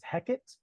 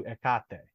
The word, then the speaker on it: Hecate: But I think most Americans would pronounce it as Hecate, the goddess of magic. – Oh, yeah. – But I think in the Greek, it's closer to Hecate.